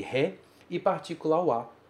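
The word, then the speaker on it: wa (は): Você tem a partícula wa, que se escreve ha, e você tem a partícula e, que se escreve re.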